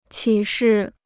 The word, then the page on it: 启示: qǐ shì